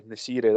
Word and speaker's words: Nesyri: Uh, the striker El Nesyri. Aye, Nesyri, that's it.